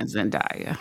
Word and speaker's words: Zendaya: Zendaya, friends. – Did I say Zendaya? – Y'all be saying Zendaya.